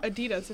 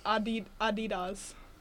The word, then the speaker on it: Adidas: They're not Adidas. It's Adidas. That's how you say it in other countries.